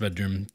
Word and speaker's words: BDRMM: When you're looking for them, look for BDRMM. But I know it's pronounced Bedroom.